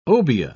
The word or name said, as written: obeah